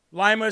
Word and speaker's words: Lima: Lima is pronounced like lima bean.